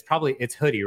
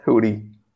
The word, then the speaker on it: Hudi: It says it's pronounced like, you know, Hudi, like the hoodie you wear. – Yeah, and I say Hudi, but then I'm guessing like, I don't know, maybe different people pronounce Hudi differently than my American accent.